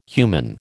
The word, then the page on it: human: American English